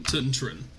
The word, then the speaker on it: Twntrwn: So the only fight between Trident and Tuntren wasn't... – Tuntren. – That's how you pronounce it. – Okay. – Spelled T-W-N-T-R-W-N.